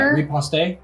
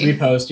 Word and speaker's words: riposte: Are we French or are we American? – We're D&D players. – Okay, so it's very nerdy. – Repost, yeah.